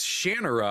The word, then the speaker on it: Shannara: It's Shannara, I found out, because Terry Brooks did an interview. He's like, yep, that's how it's pronounced.